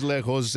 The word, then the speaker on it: lejos: Amores lejos. And yeah, it rhymes with conejos, okay?